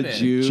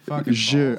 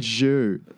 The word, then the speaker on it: Zhu: John the Jew. – John the Zhu. I said Zhu. – Zhu. – But disregarded. – Right. Soft J.